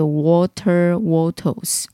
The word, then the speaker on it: Walter Wattles: 这间公司他其实早期哦，他的创办人叫 Walter Wattles，我上网看了，然后又到 YouTube 看别人怎么发音哦，应该是这样子发音啊，就是 Walter Wattles。